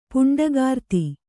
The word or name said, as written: ಪುಂಡಗಾರ್ತಿ